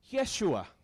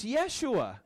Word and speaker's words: Yeshua: Did you know his name Jesus, the Hebrew pronunciation is Yeshua? Actually, when transliterated English, it's actually Joshua. Pretty cool, right? Josh Christ? Anybody? No? His name's actually Joshua. It's Yeshua.